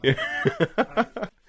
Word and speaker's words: LaTeX: LaTeX. – I've heard it pronounced LaTeX. Yeah. – LaTeX.